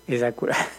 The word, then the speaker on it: Acura: Así como Lexus es la marca de lujo de Toyota, y así como Acura o Acura, así porque el señor español se enoja de que, ay, está mal pronunciado. Entonces, Acura.